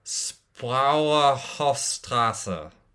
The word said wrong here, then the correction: Spreuerhofstraße: So it's Reutling, you pronounce that quite well, and the street is called Spreuerhofstraße. – Okay, Spreuerhofstraße.